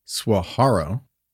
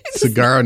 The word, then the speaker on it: Saguaro: Saguaro? Saguaro? – It's not swa. It's saguaro. – Okay. Saguaro. Saguaro National Park. – Okay. So we have trouble pronouncing this next part. – Saguaro National Park outside of Tucson.